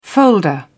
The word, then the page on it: folder: British English